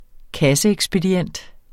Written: kasseekspedient

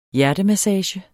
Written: hjertemassage

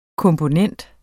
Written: komponent

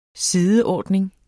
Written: sideordning

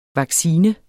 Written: vaccine

